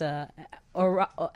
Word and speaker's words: Orion: It's Orion, like the constellation.